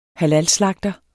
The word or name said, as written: halalslagter